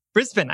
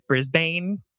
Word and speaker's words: Brisbane: Brisbane, I said it right. I had to like remind myself, don't say Brisbane.